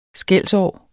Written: skelsår

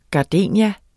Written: gardenia

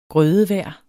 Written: grødevejr

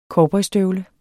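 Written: COWBOYSTØVLE

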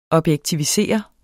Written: objektivisere

